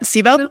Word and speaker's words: SIBO: SIBO, am I saying that right? – Yep, SIBO.